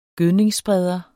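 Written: gødningsspreder

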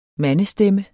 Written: mandestemme

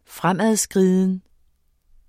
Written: fremadskriden

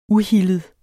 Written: uhildet

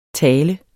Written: tale

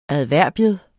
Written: adverbium

